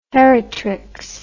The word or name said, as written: heritrix